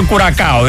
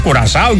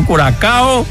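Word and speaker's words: Curaçao: Ο Τζίζες Κασέλ πιλωρέ πως είναι η σωστή προφορά, κουρασάο ή κουρακάο. Μπλου κουρακάο λέγαμε εμείς παλιά το κοκτέιλ. Οπότε κουρακάο, δεν και ο κουρασάο και κουραζό και τέτοια. Δεν λέμε Nike, λέμε Nike, λέμε κουρακάο. Λέμε κολούμπια, δεν λέμε κολάμπια.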